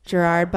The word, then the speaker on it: Gerard: And then number two, like Gerald Butler. Is that how you pronounce his name? – Gerard Butler.